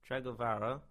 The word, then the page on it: Tregavarah: English